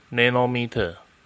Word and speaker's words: nanometer: nm 念作 nanometer，翻成中文叫做纳米。若将太阳光透过棱镜，可以看到红、橙、黄、绿、蓝、靛、紫的不同颜色的光。这些不同的光有着不同的光谱区间。例如，紫色的光约落在四百到四百五十 nanometer，